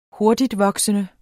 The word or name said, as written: hurtigtvoksende